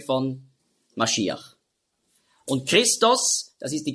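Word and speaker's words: Mashiach (משיח): Und dann Iach mit dem Het am Schluss, Mashiach, hat man früher ausgesprochen, Mashiach.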